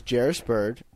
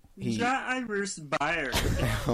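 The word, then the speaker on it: Jairus Byrd: His name is Jarris Bird. – Jairus Byer. – Oh my god. – Jairus Byer. – Their multiple pronunciations are all accepted.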